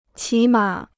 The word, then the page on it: 起码: qǐ mǎ